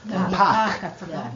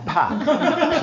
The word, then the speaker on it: Pâques: Pâques. – Pâques. P-A-Q-U. Accent circumflex, which is replacement for an S. So Pâques in French comes from Aramaic Pascha.